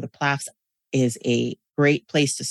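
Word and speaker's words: PLAAFP: How do you pronounce that? – We say PLAFs. To me, PLAFs. It sounds like an AFLAT commercial. PLAF. Yeah.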